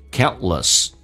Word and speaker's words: countless: In life, your failures will be countless. T 跟 L 在一起的时候，有时候 T 经常的轻轻的形成气流阻塞，不发音 countless。